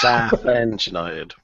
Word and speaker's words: Southend United: So I'd just like to point out, John, seeing as Jay would normally correct pronunciations, it's Southend United.